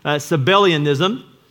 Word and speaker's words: Sabellianism: Sabellianism.